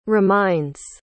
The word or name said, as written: reminds